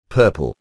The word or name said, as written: purple